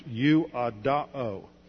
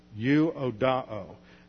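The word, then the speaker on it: U-O-D-A-O (εὐοδόω): It's the Greek word, I misspelled it in transliteration, it's E-U-O-D-O-O. That last omega is a long O. It's pronounced U-O-D-A-O. U-O-D-A-O.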